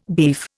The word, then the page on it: beef: Indian